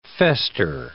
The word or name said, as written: fester